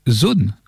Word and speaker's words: Sohn: Voilà, c'était Zone, alors ça s'écrit S-O-H-N hein, pour ceux qui connaissent pas, et c'était le titre Veto.